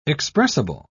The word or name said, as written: expressible